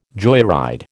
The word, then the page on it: joyride: American English